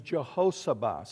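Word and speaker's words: Jehosheba: Her name is Jehoshabah. Say Jah. Hos. Ha. Bah. That's how you would pronounce her name, four syllables.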